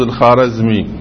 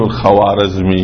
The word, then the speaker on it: الخوارزمي: هذه الواو لا تنطق في اللغة الفارسية، يقولون الخاجة ما يقول الخواجة، ما يقول الخوارزمي، هي الخارزمي، مثل خاهر برادر، الواو بعد الألف لا تنطق، بس العرب يقول الخوارزمي، هذا اشتباه.